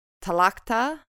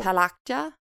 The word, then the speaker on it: Tlachtga: Just west of Atboy, Ireland, is Talacha. – How do you spell it? – T-L-A-C-H-T-G-A. – One more. – Talacha.